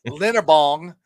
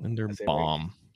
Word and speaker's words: Linderbaum: He says, every day we talk about Linderbong, and every day says his name wrong. Linderbong. There you go. Bong. – Linderbaum. Yeah. – Bomb. They're bomb.